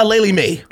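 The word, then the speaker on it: Aleali May: That's not how you pronounce her name, but yeah. – Aleli Mae. Aleli Mae 6's.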